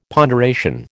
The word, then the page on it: ponderation: American English